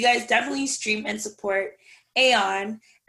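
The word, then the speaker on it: A0N: So this guy's name is Aeon, and it's spelled A space, the number 0 space, the letter N, but it's pronounced Aeon.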